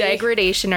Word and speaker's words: degradation: We apparently have been saying that wrong. – Sorry. – It's degradation.